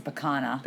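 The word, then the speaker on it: picanha: And made a really nice, I don't know how you pronounce it. It's a pecuna steak. – I looked it up. It's a pecana.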